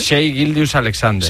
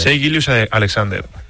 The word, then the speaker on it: Shai Gilgeous-Alexander: Shai Gilgeous-Alexander. – Shai Gilgeous-Alexander.